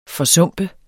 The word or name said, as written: forsumpe